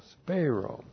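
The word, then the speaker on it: speiro: The verb spero. S-P-E-I-R, long O, spero.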